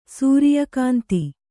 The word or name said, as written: ಸೂರ್ಯಕಾಂತ